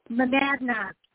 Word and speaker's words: Monadnock: Monadnock.